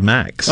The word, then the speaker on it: MAEX: How do you say it? The M-A-E-X. Do you say it? – We call it the MAX.